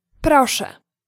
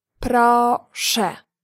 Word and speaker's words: proszę: In Polish, please is proszę. Proszę. Let's break it down by syllable. Proszę. Now let's hear it once again.